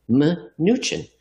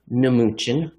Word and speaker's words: Mnuchin: Jeff, you don't often see English words with the M and N in the beginning. And when you do, the M is silent, mnemonic. Yet for some reason, we call the former Treasury Secretary Mnuchin.